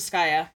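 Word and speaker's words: Skaia: It's not it's Skyea, like, what rhymes with Skyea? – Paella. – Well, I was going to say, it's not Skyea like paella. It's Skyea like French fryer.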